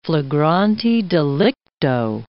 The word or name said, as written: flagrante delicto